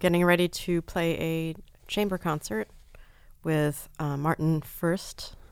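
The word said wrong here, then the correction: Fröst: I don't really know how to say his name properly. He's a Swedish, right? – Frost. – It's O with those two. Does it have the dots over it? – Yes.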